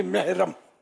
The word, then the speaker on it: محرم: یہ محرم بولتے ہیں, محرم میم ہا زبر مح, لیکن آواز وہ زیر کی نکلے گی محرم, جسے اہل سنت اصل تلفظ اہل سنت, لیکن وہ اردو میں یہ گنجائش ہے کہ اہل سنت بولے.